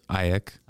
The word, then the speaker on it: AEK: I only know, like, I didn't even know how to say it, AEK.